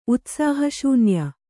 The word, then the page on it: ಉತ್ಸಾಹಶೂನ್ಯ: utsāha śunya; Kannada